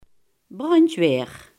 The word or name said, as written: brandweer